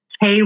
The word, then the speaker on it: Kei: And that is at Kei Rowan-Young _ photography. My name is spelled K-E-I, my first name, but it's pronounced like the letter.